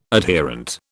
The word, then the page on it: adherent: British English